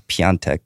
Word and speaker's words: Piątek: Andre Silver and Krzysztof Piątek. Somebody on Twitter pointed out how to pronounce that. It's Piątek. They all scored for their respective nations, keeping up their respective hot streaks.